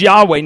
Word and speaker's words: Yahweh: This was totally new because in the Old Testament, God revealed himself as Yahweh. Now, that's how we pronounce it, but it was YHWH, capital letters, no vowels in there,